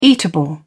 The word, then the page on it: eatable: British English